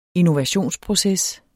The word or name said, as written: innovationsproces